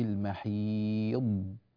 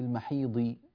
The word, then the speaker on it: المحيض: فنقول في المحيض في المحيض هكذا. والضاد عند الناطقين يعني يختلف نطقهم فيها بين واحد وآخر من ناحية الخطأ والصواب، فمن الخطأ في نطق هذه الضاد خلط صوتها بصوت الظاء أو جعلها ظاء خالصة أو جعلها دالا. بعض الناس ينطق الضاد من طرف اللسان في المحيض ضي ضي ضي ضي، هذه دال أيها الأكارم وليست ضادا،